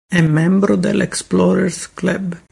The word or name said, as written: membro